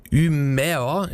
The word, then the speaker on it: Umeå: Die heißen Komeda und kommen aus der Stadt Umeå. Ich glaube, so spricht man das im Schwedischen in etwa aus.